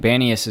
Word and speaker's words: Banias: But there is a place known as Banias, nestled at the base of Mount Hermon, of course, and Banias is an Arabic pronunciation of Pan, by the way. Yes, Pan.